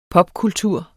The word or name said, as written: popkultur